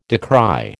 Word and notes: decry: American English

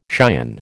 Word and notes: cion: American English

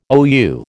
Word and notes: OU: American English

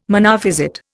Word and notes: Monophysite: Indian

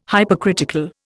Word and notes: Hypercritical: Indian

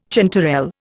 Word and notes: chanterelle: Indian

